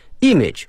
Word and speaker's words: image: image 我们知道图片这个词啊意思不难，但是呢我们在发音的时候呢要小心了，它的重音在第一个音阶上哈， image 啊不是在第二个上面。